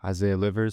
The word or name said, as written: Isaiah Livers